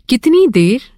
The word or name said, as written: कितनी